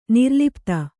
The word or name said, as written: ನಿರ್ಲಪಿತ